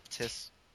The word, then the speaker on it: Nephthys: There's his mother with Osiris, which is, I cannot pronounce this. It's Nephthys, I think. It's N-E, something like that. – Nephthys.